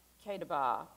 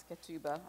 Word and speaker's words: ketubah: And then there is a marriage contract called ketubah, and I've always pronounced it ketubah, but it's not. It's ketubah.